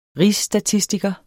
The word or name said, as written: rigsstatistiker